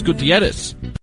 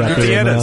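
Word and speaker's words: Gutiérrez: Crockett and Hermanos Gutierrez. – What's wrong with that? That's how you pronounce it. – He's proud of you. – Gutierrez.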